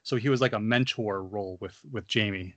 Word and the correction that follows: Jaime: and Jaime, sorry, Jaime, I should say that right.